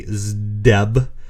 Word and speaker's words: Zdeb: said Wendy Zdeb. I think is how you say it. There's no other way to pronounce that. Z-D-E-B.